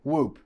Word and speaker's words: Whoop: because it's Whoop, not it should be Whoop. – Whoop. – Yeah, I guess it rhymes with a loop.